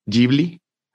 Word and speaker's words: Ghibli: Oye, y si no me equivoco, esto es de Estudio Ghibli.